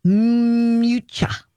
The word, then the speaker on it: Miuccia: Miuccia.